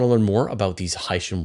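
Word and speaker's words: Hycean: Aaron, before we get started, I need some help with the pronunciation. Many people are calling, so it's hydrogen ocean worlds. And so, you know, I'm an English, you know, native English speaker. Uh-huh. For me, I would pronounce that Haitian world. But then when you say it's hydrogen and ocean, mm-hmm. then you say Haitian. – Yes, you're in the field. I've also been told that Haitian is the right pronunciation. – Yes, Haitian is the correct pronunciation.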